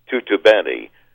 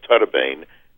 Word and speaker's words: Tuttobene: And my given name, and I'll explain why I changed it, my given name should properly have been pronounced Tutubeni. We anglicized it a bit and said Tutubane, and it was spelled Tuttobene.